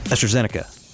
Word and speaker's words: AstraZeneca: AstraZeneca. AstraZeneca. – Oh, I do not emphasize the first part of that syllable. – AstraZeneca.